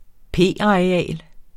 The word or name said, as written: p-areal